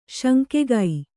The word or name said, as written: ಶಾಂಖಿಕ